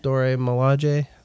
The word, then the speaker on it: Dora Milaje: How do you say that? – Dora Milaje.